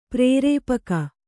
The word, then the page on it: ಪ್ರೇರೇಪಕಿ: prērēpaki